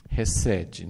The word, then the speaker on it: hesed: É tudo assim uma coisa muito boa, mas eu tenho de vez em quando chamado a atenção para essa palavra que é hesed, né? Às vezes é transliterado com CH, né? Mas é pronunciado mais ou menos, né? Hesed.